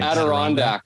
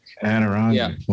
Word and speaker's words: Adirondack: Adrionic chairs. Is that how you pronounce it? Yeah, they were very nice. – Adirondack. – Adirondack. Yeah. Wow. – That's right.